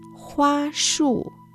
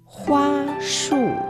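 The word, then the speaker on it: hua shu (花束): Y ramo de flores, hua shu, hua shu. Hua ya sabemos significa flor y shu en este lugar quiere decir ramo. Hua ramo de flores. Hua shu.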